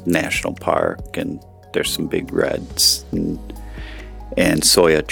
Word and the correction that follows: sequoia: trees, and am I saying it right? Soya? – Sequoia. – Sequoia.